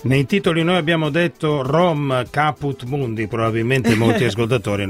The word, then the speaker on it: Rhom: Solo che si scrive R-H-O-M, quindi R per Roma, home è la casa.